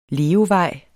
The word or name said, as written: levevej